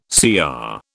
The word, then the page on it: CR: British English